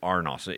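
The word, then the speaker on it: arnos (ἀρνός): The word lamb is the word is arnos. You pronounce it arnos in that language.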